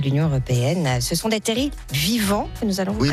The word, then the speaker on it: terrils: Bonne prononciation, parce qu'on ne dit pas en effet des terrils, mais des terrils. – Moi j'aurais dit terrils, c'est vous qui m'avez expliqué. – Oui, mais moi je ne savais pas avant de venir ici. Hein. Le L en fait ne se prononce pas comme on ne dit pas un fusil, mais un fusil.